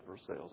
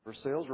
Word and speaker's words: Versailles: But if you ever near the airport there, you'll see on the Versailles Road, that's the way they pronounce it, Versailles, I know what I'm saying, Versailles Road, just west of Lexington, by the airport, there is what looks like a medieval castle.